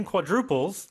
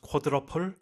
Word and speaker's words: quadruple: And just be careful of the pronunciation because if you've watched figure skating here in Korea, this is pronounced quadruple, but it's a quadruple. There's a U in there.